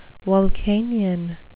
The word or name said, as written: vulcanian